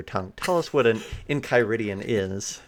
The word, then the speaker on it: Enchiridion: This is called Izerians Enchiridians. Hope I said that right. – I think it's Enchiridion. – Enchiridion of the West Marches.